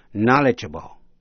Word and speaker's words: knowledgeable: K-N-O-W-L-E-D-G-E-A-B-L-E, knowledgeable. Tính từ có nghĩa là hiểu biết nhiều, am tường vấn đề. Để ý lại cách phát âm của ba chữ know, động từ, danh từ knowledge, tính từ knowledgeable. Quý vị nghe lại, know, knowledge, knowledgeable. Quý vị sẽ nghe tính từ final, F-I-N-A-L, final là cuối cùng.